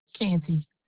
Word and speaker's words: Canty: Geraldine Canty. – Geraldine. G-E-R-A-L-D-I-N-E. – No. J. – J-E-R-A. – Uh-huh. – L-D-I-N-E. – Uh-huh. – And the last name? – Canty. – How do you spell that? – C-A-N-T-Y. – C-A-N-T-Y.